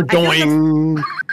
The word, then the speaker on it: Dordogne: Dordogne. – I mean, if it's a French word, it would be like Dordogne. – That's kind of that sounds more right.